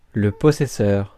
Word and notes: possessor: /pɒˈzɛsɔː/

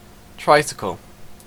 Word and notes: tricycle: /ˈtraɪsɪkəl/; /ˈtraɪˌsɪkəl/

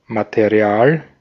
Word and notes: matériel: France; [ma.te.ʁjɛl]

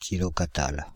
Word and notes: kilokatals: France (Île-de-France); /ki.lɔ.ka.tal/

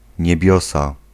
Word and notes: niebiosa: [ɲɛˈbjɔsa]

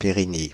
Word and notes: Périgny: [pe.ʁi.ɲi]; France (Île-de-France)

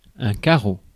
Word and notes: carreau: France; /ka.ʁo/

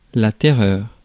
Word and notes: terror: American English; /ˈtɛɹɚ/

